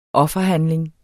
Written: offerhandling

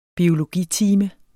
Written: BIOLOGITIME

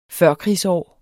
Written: førkrigsår

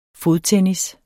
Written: fodtennis

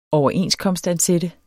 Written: overenskomstansat

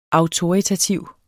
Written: AUTORITATIVE

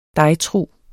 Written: dejtrug

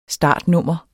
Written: startnummer

